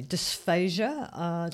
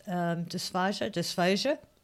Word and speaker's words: dysphagia: Whilst I was learning this and after treatment, someone said a word to me, dysphagia, that's how I pronounce it, dysphagia.